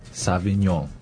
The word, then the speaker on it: Sauvignon: So one of the most common types of red wine probably is Cabernet Sauvignon. – Right, you see that a lot. – 这个听起来有点难念，然后也不像英文。 – Because it's French. – Exactly. 那这个 Cabernet Sauvignon 就是加本利苏维翁，对吧？这样不知道。 – Of course. – 你把这个读音再来跟我们慢慢的再读一遍，好不好？ – Cabernet. – Cabernet. – Sauvignon.